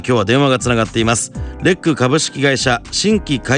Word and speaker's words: LEC: さあ、まずは松本さん、はい、社名のレックというこの名前の由来から教えていただけますか。 – はい、レックは LEC でレックと読みます。